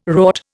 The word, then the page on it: rort: Indian